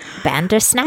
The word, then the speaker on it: Bandersnatch: Kick off the new year, if you will, with Bird Box and Black Mirror Bandersnatch, which are both streaming on Netflix. Did I say that wrong? – No, it's just the funniest word ever. – It is Bandersnatch.